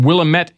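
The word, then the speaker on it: Wilmette: Now here's a teen beat combo called The Few from Willamette, Illinois. Now, that's how they pronounce it there. They say Willamette instead of Willamette like we do here.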